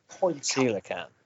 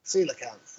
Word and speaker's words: coelacanth: Well, I can never say this properly. There's the prehistoric fish. – Coelacanth. – Coelacanth, that's the one.